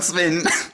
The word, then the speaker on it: Sven: I'm Sven.